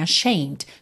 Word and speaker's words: ashamed: Olha que interessante, a palavra ashamed, o E não é pronunciado, então você teria só esse Dzinho, né, ashamed, só um Dzinho leve depois do M,